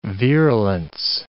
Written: virulence

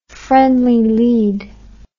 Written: friendly lead

